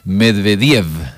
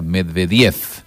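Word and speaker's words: Medvédev: La mayoría de los apellidos son con acentuación esdrújula, excepto Medvediev, donde la última V se pronuncia F, o sea, Medvediev.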